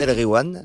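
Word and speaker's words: Nass El Ghiwane: Nas El Gwiam. – Nas El Gwiam, voilà. Et quand il y a GH, tu dis re. Tu fais comme si c'était un R.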